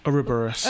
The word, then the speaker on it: Ouroboros: Ouroboros.